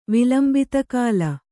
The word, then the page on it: ವಿಲಂಬಿತಕಾಲ: kannada